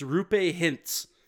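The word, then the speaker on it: Roope Hintz: It's Rupe Hintz. It's literally phonetically correct, but it looks all fucked up.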